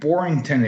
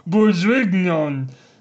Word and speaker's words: Bourguignon: I still don't know what beef Bourguignon is. – First of all, I don't think you said that right. It's beef Bourguignon with crushed potatoes.